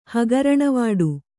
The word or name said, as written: ಹಗರಣವಾಡು